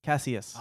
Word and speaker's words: Cassius: So, what do you say his name was Cassius or Cassius? – Cassius.